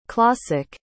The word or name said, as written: clawsick